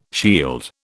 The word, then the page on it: shield: British English